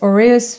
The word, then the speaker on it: Aureus: So it's Aureus Financial. Is this how you pronounce it? – Correct. You nailed it.